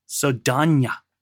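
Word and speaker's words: Saldaña: Saldana. That's how you actually say her name. It's kind of like Lindsay Lohan is actually Lindsay Lohan. Something else we learned earlier this year. Her name isn't Zoe Saldana. It's actually Zoe Saldana.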